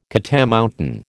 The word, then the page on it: catamountain: American English